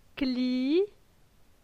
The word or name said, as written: Cli